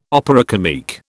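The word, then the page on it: opéra comique: British English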